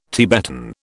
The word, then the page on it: Tibetan: British English